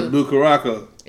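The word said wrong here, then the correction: Curaçao: I'm pretty sure that's not how you say it. Shit. About I think it's Curacao.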